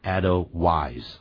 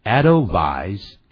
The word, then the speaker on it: Edelweiss: 那么这里头呢，就有一首歌曲叫《 Edelweiss》，也就是我们今天带给大家的这首歌曲。那么的歌词简单，所以我从头到尾念一下，《 Edelweiss》。不要念成 Edelweiss 啊 Edelweiss Edelweiss